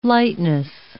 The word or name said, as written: lightness